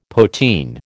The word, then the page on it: poteen: American English